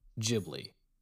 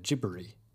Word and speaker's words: Ghibli: But their American translators call it Ghibli because it's easier for Americans to say Ghibli instead of gibbery, because there's like a weird RL sound at the end of the Japanese way to say it that Americans have a really hard time of saying.